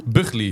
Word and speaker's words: Buckley: Zeg ik het goed uit? Buckley? – Nee, je spreekt het echt helemaal niet goed uit.